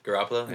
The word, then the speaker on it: Garoppolo: Mahomes and Garoppolo. Is that how you say it? – Garoppolo. Yeah.